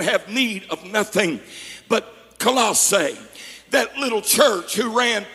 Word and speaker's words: Colossae: If you study Colossae would be the correct pronunciation.